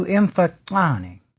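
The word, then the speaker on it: Mfecane: Actually, if I were to pronounce that correctly, it would be something close. I'm only going to try this once. To Imfokane. There would be a clicking sound there,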